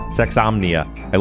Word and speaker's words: Sexsomnia: And I actually just myself listened today to your episode on the sexopathy. Am I saying that right? – Sexsomnia.